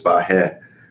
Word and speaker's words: Vahe: Speaking as one, I, by the way, I'm Armenian, and, and I've been told all my life my name's pronounced Vahe, but I, there's a very Armenian friend I have who, who thinks I mispronounced my name, and it's Vahe.